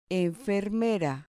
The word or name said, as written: enfermera